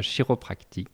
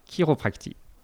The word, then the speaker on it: chiropractie: Chiro, ça s'écrit C-H-I, ça ne se dit pas chiropractie, mais bien chiropractie.